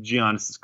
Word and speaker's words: Giannis: and Tim Hardaway Jr. is cowering in Giannis's crotch. – Say it with me. – It's just like, no, God. – Soft G. Giannis. It's like yogging.